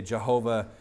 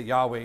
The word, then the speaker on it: Yahweh: Some say Jehovah. People really fight about how to pronounce that. I'm convinced that Yahweh is the right way.